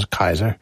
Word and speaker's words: Kaiser: There's no C originally in the German language, so you substitute a K and the pronunciation becomes Kaiser.